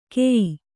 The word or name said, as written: ಕೆಯ್ಯಿ